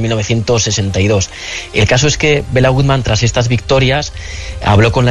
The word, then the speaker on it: Béla Guttmann: Hablamos en la introducción la de Bela Goodman. ¿Quién es? No sé si se dice así. ¿Quién era este personaje? – Pues Béla Guttmann fue un entrenador que nació en el año 1899 en Budapest.